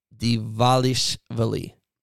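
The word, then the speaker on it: Dvalishvili: It's spelled D V A L I S H and then a V I L I. And I have it phonetically spelled out here. And honestly, like, I, I'm botching it. Merab Divalishvili. That's as close as I'm going to get.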